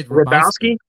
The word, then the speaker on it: Hrabowski: I think it's a silent H. I just say Hrabowski. – Yeah. – Hrabowski.